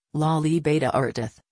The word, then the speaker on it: La Libertad: La Libertad, Spanish pronunciation, La Libertad, is a region in northwestern Peru.